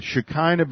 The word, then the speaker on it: Shekinah: And notice when Shekinah begins with a SH sound in English, but this represents one letter in Hebrew called a sheen. And that is pronounced uh, as SH.